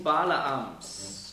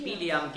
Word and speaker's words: Bileam: Balaam, das ist einfach die griechische Aussprache von Bileam. Das ist das Gleiche, ja? Im Neuen Testament werden oft Namen aus dem Alten Testament anders ausgesprochen, weil sie eben griechisch ausgesprochen werden.